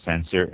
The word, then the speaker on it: Sancerre: And Sancerre, S-A-N-C-E-R-R-E, Sancerre.